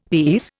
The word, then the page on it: these: Indian